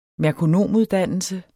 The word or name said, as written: merkonomuddannelse